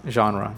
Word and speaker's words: genre: Okay, I mean, genre is another one that I keep bringing up. – Right. – Genre. Genre mm-hmm. is a French word.